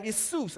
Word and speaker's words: Iesus: But when we translate that over, we take our name from the Greek name Isus, and it's spelled I-E-S-U-S. And all we did was change the I to a J, and we pronounce it Jesus.